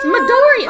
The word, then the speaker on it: Midoriya: It's Midoriya. Oh, the emphasis matters, and this is where English speakers fucking fail, because in Spanish, French, Japanese, the way the shit is written tells you which syllable has the emphasis.